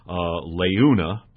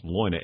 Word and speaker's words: Leuna: I thought it was pronounced Leuna, but it's actually pronounced Leuna, L-E-U-N-A.